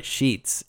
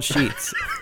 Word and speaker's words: Sheetz: So apparently the police think that these two were also suspects wanted in connection with a larceny at a Sheetz convenience store. And I said that right. Sheetz. It is called Sheetz.